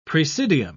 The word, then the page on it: praesidium: British English